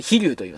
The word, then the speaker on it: 飛竜: 七番引き山が、飛竜、飛ぶ、立つ、飛ぶ竜と書いて飛竜と言います。これはまあ、お魚ですね。